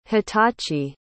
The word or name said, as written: hitachi